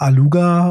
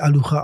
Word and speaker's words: Alugha: Ähm, es, also es kommt tatsächlich aus dem arabischen Raum und dort würde man es tatsächlich Alucha aussprechen, aber für uns ist es immer Aluga.